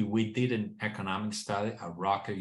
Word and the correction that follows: Rutgers: University, close to you, Gary, and it's, did I pronounce it correctly? – Rutgers University.